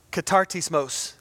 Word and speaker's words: Katartismos: Katartismos is the way I learned to pronounce that.